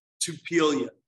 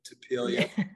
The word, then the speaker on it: Tupelia: but this was started as a project called Tupelia, Tupelia. And basically, you know, phonetically like tequila, but Tupelia.